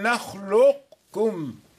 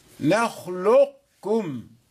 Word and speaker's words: نخلقكم: وأما ألم نخلقكم فبعض أهل الأداء ينطقونها يدغمونها إدغاما ناقصا، يعني يعطي صفة التفخيم للقاف، تبقى هذه الصفة فيقرأ ألم نخلقكم نخلقكم.